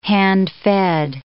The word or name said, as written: hand-fed